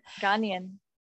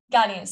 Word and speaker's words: Ghanaian: How many times would you just go to like a, Okay, for example, Gayen, is that how you say it? No, I'm not gonna, why am I complaining? – What is Gayen? – I was gonna say from Ghana, but I don't know how to say it. – Ghanaian. – Ghanaian, sorry, that's it.